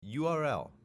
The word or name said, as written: URL